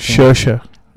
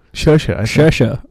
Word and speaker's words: Saoirse: Saoirse Ronan. – Saoirse? – Saoirse. – Excuse me for mispronouncing.